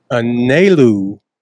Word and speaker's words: Anelu: So allow me to reintroduce Anelu.